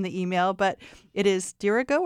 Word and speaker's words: Dirigo: Accent on the antepenult. – Yes. – What does dirigo that mean, Luke? – So that means the accent is not on the last syllable. It's not on the syllable before the last syllable. It's on the syllable before that.